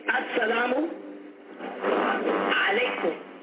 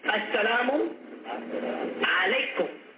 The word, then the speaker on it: As-salamu alaykum: Say, As-salamu alaykum. As-salamu alaykum.